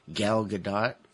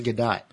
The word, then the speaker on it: Gal Gadot: And then, of course, it's, her name is so, is always, I've heard of many different pronunciations, but I think it's supposed to be Gal Gadot. – Gal Gadot. – I heard it was Gadot.